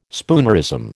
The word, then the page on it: spoonerism: American English